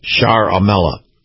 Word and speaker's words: Ciaramella: But his name is actually spelled C-I-A-R-A-M-E-L-L-A, but it's pronounced Sciaramella.